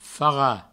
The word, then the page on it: farrat: Provençal